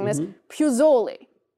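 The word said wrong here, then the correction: Pozzuoli: I would say you need a little more, you know, you get the face down. Puzzuoli.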